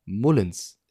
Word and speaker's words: Mullins: Ähm, Mullins, der Backup-Quarterback, heißt wohl Mullins. Es gibt immer wieder Neues, was man lernt in Aussprache.